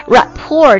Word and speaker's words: rapport: Rapport 注意一下啊我第一次读的时候我把这个 T 加上去那这个单词它的读音是没有特音的它读作 Rapport R-A-P-P-O-R-T